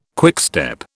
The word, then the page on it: quickstep: British English